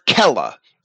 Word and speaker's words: cella: The Latin word kella is a cell or a compartment. And in classical Latin, the letter C is hard. It's not soft like the English S. It's a hard C, and we can know that from the way that the Romans had transliterated Greek place names and other words into Latin.